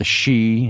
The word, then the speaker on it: sidhe: He's written about the she, and if any of you see this word, it's spelled S-I-D-H-E, the she, or the she, and it's talking about, you know, the banshee, and things like that.